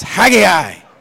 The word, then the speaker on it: Haggai: We've had a debate whether it's Haggai or Haggai, like I say it. How many of you pronounce it simply Haggai? You're boring. It's Haggai. And if you want to get really Hebrew, you got to get the right there with the Hebrew language.